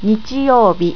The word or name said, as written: nichiyoubi